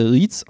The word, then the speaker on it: REITs: Bevor wir aber tiefer mit der Thematik beginnen, möchte ich noch darauf hinweisen, dass REITs richtigerweise REITs ausgesprochen wird, einfach weil das E im Englischen wie ein I gesprochen wird. Ist so eine kleine Marotte von mir, das REITs zu nennen.